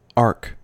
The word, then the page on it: ark: American English